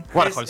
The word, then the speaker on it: Warhol: Ah, listo, Warhol, sí.